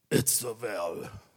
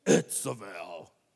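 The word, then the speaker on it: Itzavel (איזבל): We know her as Jezebel, but I like the Hebrew name. Itzavel. You got to say it like that. Itzavel.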